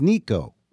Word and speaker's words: Nico: I've been calling you Nico, but I was reading something recently that said the correct pronunciation is Nico. – No, there isn't any correct way. If you just think of Nick with an O on the end of it, that's how it's spelled.